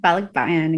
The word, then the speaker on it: Palakbayan: And Analiza is also an acclaimed author with books including, can you say is that in Tagalog, Palakbayan? – Mm-hmm. Palakbayan, exactly.